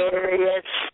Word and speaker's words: Arias: of the things that came out today was Jodi Arias, or the Aries. How do you say her name? Jodi Aries. Um, Jodi Arias.